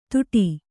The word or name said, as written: ತುಟಿ